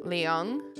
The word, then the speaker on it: Leung: What, L-I-A-N-G? – It is L-E-U-N-G.